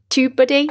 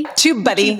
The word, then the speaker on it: TubeBuddy: A really, really good tool is something called YouTube Buddy. Did I say it right? TubeBuddy. TubeBuddy.